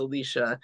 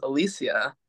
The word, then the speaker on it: Alicia: So my real name is Alicia. It's spelled Alicia, which in Hispanic cultures is Alicia.